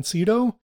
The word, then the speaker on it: Sydow: That's the way I'm going to pronounce his last name because everybody says it different. And I had a friend in elementary school whose last name was Sydow. And he's like, oh yeah, Max von Sydow is a relative of ours.